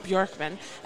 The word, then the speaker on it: Bjorkman: Bjorkman.